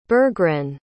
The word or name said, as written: bergren